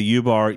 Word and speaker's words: UBARRE: So yeah, the U bar, it's spelled UBARRE, like in posture, and then it would be plural, would be U bars.